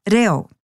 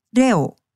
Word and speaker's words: reo: So if you know that Māori vowel sounds are a, e, I, or u, bring that knowledge to the word, reo, reo. Splitting any Māori word into syllables that end with a vowel will help you get your head around how to tackle the word, reo.